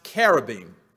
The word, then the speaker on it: cherubim: Now, I'm going to tell you right up front that the word is pronounced carabim or carob. You're going to hear a lot of my Baltimore E's coming out because when I see C-H, my mouth says ch, but it should be k.